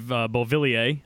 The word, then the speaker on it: Beauvillier: Sticking with the youth also, I know you're a big fan of Beauvillier. I actually said it right that time.